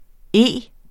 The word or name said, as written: E